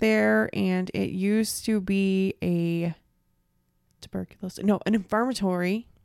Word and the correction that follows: infirmary: Did I say that right? Infirmatory. You're getting to me. – I'll blame it on me. – Yeah, I am. An infirmary.